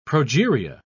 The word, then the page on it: progeria: British English